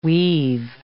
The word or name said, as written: we've